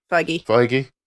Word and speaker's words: Feige: Speaking of, Kevin Feige, is that how you pronounce his name? – Feige.